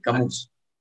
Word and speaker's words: Camus: Bueno, Camus, creo que es, Camus porque es francés. Era un filósofo francés, entonces como los franceses son raros, usualmente las últimas eh, palabras, las últimas letras no se pronuncian, entonces como Camus.